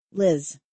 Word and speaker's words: Liz: It's a short I, actually. Liz.